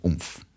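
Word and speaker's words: Oomph!: Y, bo moją następną piosenką, której chcę powiedzieć, jest piosenka zespołu UMF.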